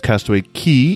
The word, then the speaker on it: Castaway Cay: And it is Castaway Key, even though it is spelled C-A-Y-K.